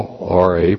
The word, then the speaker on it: ra: The word is Ra, R-A, pronounced like Ra, R-A-H, Ra, meaning bad, evil.